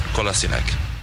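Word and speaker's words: Kolašinac: Sead Kolasinac. – Kolasinac. So we're going to just... – Kolasinac. – Kolasinac. – Kolasinac. Kolasinac. The emphasis on the C.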